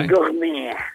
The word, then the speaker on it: gourmet: Gourmet, eller jag vet inte hur det uttalas det här. – Gourmet. – Lite som fransé, ja.